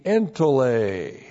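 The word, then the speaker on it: entolē: If we have dia plus the genitive, by means of the entole. E-N-T-O-L, long E, entole.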